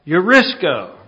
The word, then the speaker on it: Eurisko: Eurisko. Rhymes with Crisco.